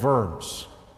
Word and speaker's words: Worms: It happened at a place called Worms. It looks like Worms, but you got to give it that German pronunciation so people don't giggle when you say the word.